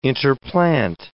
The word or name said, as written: interplant